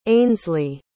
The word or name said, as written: ainsley